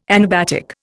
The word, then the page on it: anabatic: Indian